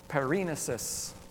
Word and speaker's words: paraenesis: But perinesis is spelled P-A-R-A-E-N-E-S-I-S.